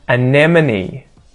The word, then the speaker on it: anemone: Anemone. Anemone. Anemone. Again, that emphasis on the second syllable. Anemone. This is one I used to screw up. I used to say amenemy or anemone.